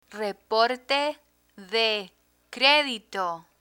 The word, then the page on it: reporte de credito: spanish